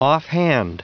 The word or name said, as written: offhand